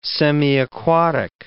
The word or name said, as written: semiaquatic